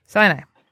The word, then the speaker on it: Sinai: Sinai? Am I saying that correctly? – Sinai.